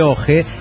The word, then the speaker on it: Oje: Soy del pueblo Santiago de Oje, que se pronuncia Oje como ojo.